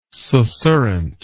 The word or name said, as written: susurrant